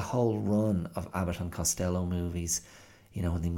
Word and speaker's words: Costello: Abbott and Costello, or if you want to go for the Irish pronunciation, Abbott and Costello.